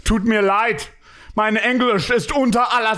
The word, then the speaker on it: Tut mir leid: The tone is right, but I just don't. The pronunciation is slightly off. – Mein English is under alla sus. – Mein English is under. – Sounds unter. – Like he's struggling with English. – Tut mir leid, mein Englisch ist unter aller Sau.